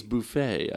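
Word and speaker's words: Buffay: Which of TV's friends was the identical twin of Ursula Buffy, a character on Mad About You? – It would have to be... – Phoebe? – Yes, absolutely. It's Phoebe. I mispronounced Buffy. It was Buffet, I believe.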